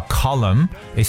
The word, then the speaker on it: column: 专栏呢，或者说一个柱形、圆柱形的这么一个词呢，叫 column，it spells c o l u m n，column。所以各位记住，就是 m n 的组合呢，m n 这个组合 n 呢是不发音的。